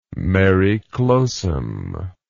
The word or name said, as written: mare clausum